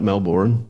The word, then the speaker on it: Melbourne: Because we're Australian, we say but Melbourne. – How do you spell it, motherfucker? – Well, exactly as it's not pronounced. – Yeah. I was making a point for everybody else out there in the world. It's Melbourne. We don't spell it like that, but that's how you say it.